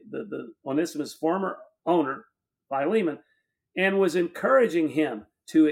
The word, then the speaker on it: Philemon: well, I think the way I heard it pronounced is Philemon,